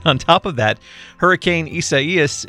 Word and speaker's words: Isaias: But according to people who know, it is pronounced Isaias. So say it with me. Isaias.